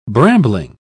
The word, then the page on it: brambling: British English